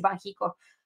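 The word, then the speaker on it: Banxico: Entonces ya no sé ni cómo se pronuncia, si es el Banchico, el Bancico, el Banjico. Muchas gracias por la acotación. Ahora de ahora en adelante yo lo voy a decir Banjico.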